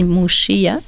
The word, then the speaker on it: Muxía: Muxía, ça s'écrit M-U-X-I-A. En gallego, on dit Muxía.